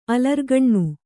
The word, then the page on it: ಅಲರ್ಗಣ್ಣಿ: alargaṇṇi